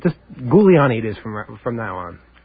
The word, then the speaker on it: Giuliani: It's pronounced Gilly... – Giuliani. – Giuliani.